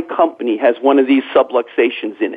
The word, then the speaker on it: subluxations: That is that there are these things called subluxations, or some people mispronounce it and say they're subway stations,